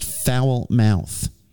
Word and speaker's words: Falmouth: No, it's pronounced foul mouth. – Is it really? – Just like everybody else.